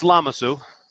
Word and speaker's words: Lamassu: So it's Lamasu.